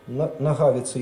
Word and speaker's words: Нагавица: Во-первых, потому что все-таки ударение в имени, в фамилии автора на второй слог, Нагавица, Яромир Нагавица, это чешский бард.